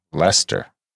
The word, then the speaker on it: Leicester: Also, Leicester is pronounced Lester.